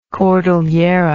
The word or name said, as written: cordillera